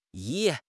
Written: yi3

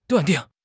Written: 断定

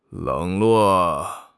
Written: 冷落

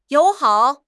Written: you3 hao3

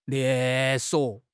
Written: 连锁

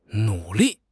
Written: nu3 li4

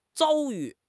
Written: 遭遇

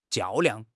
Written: 较量

